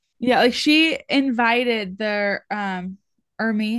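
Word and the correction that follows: Hermès: how do you say it? Hermes, Hermes. – Hermes.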